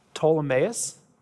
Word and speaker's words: Ptolemais: When he had finished the voyage from Tyre, we arrived at Ptolemais. A silent P, like in pterodactyl, right?